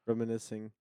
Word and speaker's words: reminiscing: Reminiscing. Not reminiscing. – Reminiscing. The C is silent.